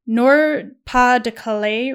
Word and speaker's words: Nord-Pas-de-Calais: which is in Nord-Pas-de-Calais region of France.